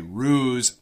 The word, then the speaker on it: ruse: Some people might say ruse, but almost always we say ruse. R-U-S-E. It has a Z pronunciation.